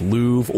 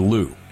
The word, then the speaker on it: Louvre: It's whether, when I said Louvre, whether it's Louvre or Lou. – Yeah. – And, you know, I don't really care. I've heard it pronounced both ways.